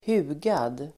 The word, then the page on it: hugad: [h'u:gad]